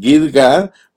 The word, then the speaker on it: Gidgad: Entonces se puede pronunciar Gidgad o se puede pronunciar Gudgoda. Cuando está transcrito aquí, en una parte aparece Gidgad, en otra aparece Gudgoda, pero ese Gidgad y Gudgoda tienen la misma Gimel.